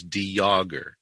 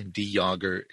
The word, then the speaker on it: de Jager: The pronunciation that we use is De Jager. It's a very silent J. De Jager. It's a French pronunciation, which fits in with the distant ancestry of ours.